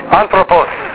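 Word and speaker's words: Anthropos (ἄνθρωπος): First word, how do you pronounce that word? Anthropos.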